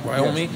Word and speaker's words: Wyoming: Like even with the Wyoming move, like, I said it right? Wyoming? – Yes.